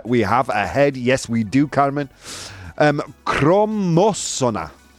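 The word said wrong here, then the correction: cromosoma: Chromosoma. So, all right, I spelled. I said it wrong. Natch. I said it wrong chromosoma.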